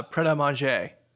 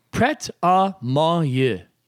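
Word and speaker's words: Prêt à Manger: How do you pronounce this restaurant? Do you have any idea what restaurant this is? Yeah, uh, Pret a Manger. final answer? Yeah. Uh, it is Prête à manger. Prête à manger.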